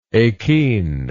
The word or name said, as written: akenial